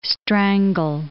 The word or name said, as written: strangle